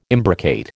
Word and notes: imbricate: American English

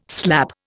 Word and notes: slap: Indian

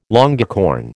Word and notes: longicorn: American English

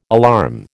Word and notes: alarm: American English

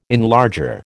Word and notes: enlarger: American English

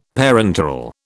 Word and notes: parenteral: British English

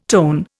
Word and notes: tone: Indian

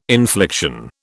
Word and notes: infliction: British English

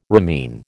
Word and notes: ramin: American English

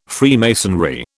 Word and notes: Freemasonry: British English

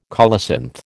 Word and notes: colocynth: American English

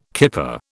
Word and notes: kipper: British English

